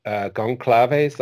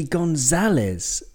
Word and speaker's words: Gonçalves: Yeah, it looks like Gonçalves. Okay, I'm big on my pronunciation these days. But it's actually Gonzalez. Gonzalez, You don't pronounce the V, and the C with the little number five on the bottom is like a Z or like an S sometimes, but Gonzalez.